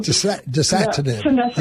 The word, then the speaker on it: dasatinib: So we tried to put him on the Savinib. Is that pronounced wrong? – Dasatinib.